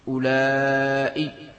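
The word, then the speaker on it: أولاء: إذن ولجمع المذكر والمؤنث أولئي هكذا بالمد، بالمد معناها بهمزة بعد الألف أولئي.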